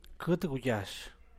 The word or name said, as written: qut guyash